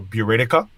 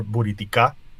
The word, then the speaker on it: Buriticá: along my last name is uh, B U R I T as in Tom, I C as in cat, A. So Buritica or Buriticá for those who, who speak Spanish.